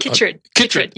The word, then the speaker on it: Chytrid: Now, you work on frogs and, of course, this scourge of the, I'm going to say it wrong, citrid fungus? Chytrid fungus? – Chytrid. Yes.